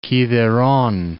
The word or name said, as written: Kithairon